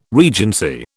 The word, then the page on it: regency: British English